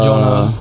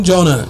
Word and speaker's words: Jona: So when I go to Starbucks, they're like, how do you say your name? Jonah. J-O-N-A, no H.